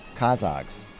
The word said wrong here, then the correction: Kazakhs: Five Kazakhs, dude. Kazakhstan. Kazakhs.